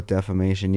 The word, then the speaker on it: defamation: Oh, defamation. Yeah. – What did I say? Defamination. – You said defamination.